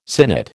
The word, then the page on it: sinnet: American English